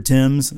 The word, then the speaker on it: Thames: Taught us how to correctly pronounce the River Thames.